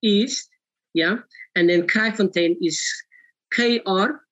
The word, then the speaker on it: Kraaifontein: But our address is 89 Horrock Street, Peerless Park East in Kraaifontein, Cape Town, South Africa.